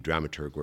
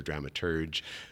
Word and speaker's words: dramaturg: Like it's either dramaturg or dramaturge, spelt with a G-E, soft G, they're both right. There's regional preferences.